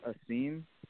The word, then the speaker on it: Asim: My first name is Asim.